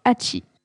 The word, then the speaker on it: Hachi: qui se prononce Hachi.